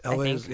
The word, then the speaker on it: Elwes: Elwes, yeah.